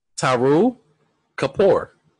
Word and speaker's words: Taru Kapoor: I want to start off by saying that I'm pretty sure you butchered his name. – Yeah, I pray. No, Taru Kapoor. – Taru Kapoor? – Yeah, T-A-R-U. – Okay. – K-A-P-O-O-R. – Okay, fair enough. Yeah.